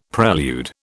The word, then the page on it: prelude: British English